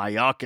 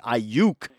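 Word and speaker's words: Aiyuk: Really good offensive performance again by Brandon Ayuk, who actually Kevin Harlan was mispronouncing his name. He's calling him Ayuk. It's Ayuk.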